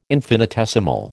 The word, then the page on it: infinitesimal: American English